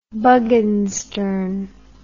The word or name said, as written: bugginssturn